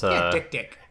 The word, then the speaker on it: dik-dik: No, it's spelled D-I-K-D-I-K. It's Dick Dick.